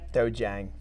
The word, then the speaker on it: dojang: So we're at Master Pagano's Red Dragon Martial Arts Dojang. Did I say that right? – Dojang. Very okay. Close.